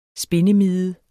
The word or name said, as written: spindemide